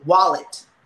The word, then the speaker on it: Ualett: It's U-A-L-E-T-T. Not to be mistaken for W-A-L-L-E-T. No. Yes. U-A-L-E-T-T. Wallet. – It's U-A-L-E-T-T, but we pronounce this as Wallet.